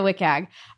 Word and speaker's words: WCAG: Is that how you pronounce it? – Regards to, or is that like a very Swedish thing to do to just pronounce the acronym? No, no, no, no. People say the WCAG.